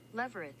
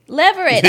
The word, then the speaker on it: levirate: But now how you say the word again? Leveret? – We just pronounced it. I know. – It's something called leveret marriage. It's spelled L-E-V-I-R-A-T-E. – Leverett. – Leverett. I did have it right, job.